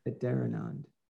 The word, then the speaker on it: Adharanand: It's Adharanand is how I, so the H is definitely there, whereas I, Adharanand, I do it like that. Adharanand. – Adharanand, right. – It's a rhyme with another land, Adharanand.